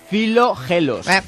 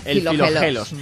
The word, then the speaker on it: Filogelos: Te lo voy a leer bien porque mi filología... – Sí, pronuncia. – Filogelos. Filogelos.